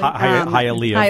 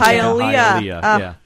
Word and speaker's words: Hialeah: Hialeah. Very close, Jessica. Hialeah. Thank you.